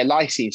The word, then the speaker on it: lysine: Is it, how do I say it? It's lysine, is that right? – Yeah, lysine.